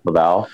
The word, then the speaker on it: LaValle: So it was a Tenerife Du, Stephen Barnes, Gerald Edwards, and Victor LaValle, I think is how you pronounce it. – LaValle.